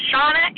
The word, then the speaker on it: Shauna: This is Shauna H., Sean, like the Irish name, with an A at the end.